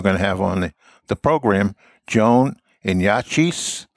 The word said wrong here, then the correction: Yatish: See, that's screwing up your pronunciation. It's Yatish and Joan.